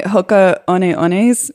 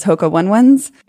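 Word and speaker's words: Hoka One One: My Hoka One Ones, which I formerly pronounced as Hoka One Ones.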